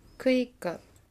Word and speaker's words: クイッカ: クイッカ、はい、いいですね。クの上にですね、アクセントが来ちゃうということで、クイッカです。